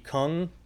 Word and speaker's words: Kueng: Alexander, and I can't pronounce his last name, K-U-E-N-G, Kung, K-U-E, was spotted at a Cub Foods shopping for groceries in Plymouth, Minnesota over the weekend.